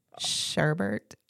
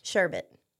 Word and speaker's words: sherbet: Sherbet. – Ew. – Yeah. – There's not an R? – There's no R at the end. So it's S-H-E-R-B-E-T.